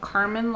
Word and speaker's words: Karman: they are called Carmen Line Studios, and it's spelled K-A-R-M-A-N Line Studios.